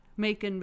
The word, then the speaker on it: Macon: The guy, Trent Ford, who plays Macon Bits. – And it is spelled bacon, but just with an M. – Wait, sorry. It's M-A-C-O-N. – It's M-A-C-O-N.